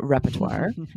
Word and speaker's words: repertoire: So you might have trouble spelling this word if you're hearing native speakers say repertoire and you don't hear that R in the middle.